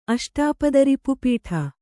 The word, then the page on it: ಅಷ್ಟಾಪದರಿಪುಪೀಠ: kannada